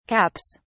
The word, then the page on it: caps: British English